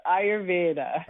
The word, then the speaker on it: Ayurveda: You came very close. It's Ayurveda. Okay, Ayurveda.